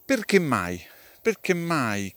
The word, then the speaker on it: perché mai: Tra queste accoppiate c'è anche perché mai che si pronuncia come se fosse tutta una parola con due M, perché mai?